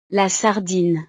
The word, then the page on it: la sardine: frances